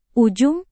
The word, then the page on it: ujung: indonesian